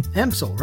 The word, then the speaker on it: Empsall: And to all of our viewers and listeners, this has been Reverend Nathan Empsall. I said that right? Empsall? Empsall? – Empsall. – Empsall.